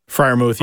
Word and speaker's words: Freiermuth: The tight end from Penn State, Freermuth. – Freiermuth, yeah. Freiermuth, I think he's going to be a very good pick in the second round, whoever gets him.